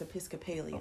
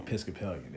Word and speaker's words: Episcopalian: I said a word wrong. What did I say? – I don't remember how you say it. You said it, but it's Episcopalian. – Episcopalian.